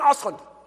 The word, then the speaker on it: Osterlund: You spell this guy's name, J-O-W-A-N. He says it so fast it sounds like he's saying Owen. J-O-W-A-N. His last name is spelled O-S-T-E-R-L-U-N-D. He says his name like this, Owen Ostlund.